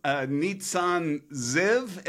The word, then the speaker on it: Neatsun Ziv: And so welcome, Neatsun Ziv.